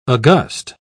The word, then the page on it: august: British English